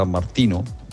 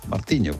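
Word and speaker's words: Martinho: Hoy vamos a tener chubascos, el domingo vamos a tener nevadas y la borrasca Martiño o la borrasca Martino. Martiño, porque se escribe con una H intercalada y eso se lee Martiño.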